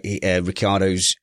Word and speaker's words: Ricciardo: That pronouncing my Ricciardo's name wrong is not funny.